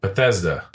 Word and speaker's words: Bethesda: Bethesda. – Bethesda. – Batista.